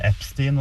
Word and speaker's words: Epstein: Jeg sier Epstein, og det vist det vi skal si, men det jeg la merke til var jo at du hørte cirka 5-6-7 ulike uttaler av det, vi som satt der, så der det mange som sier feil, men du skal si Epstein.